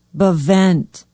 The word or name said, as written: bevent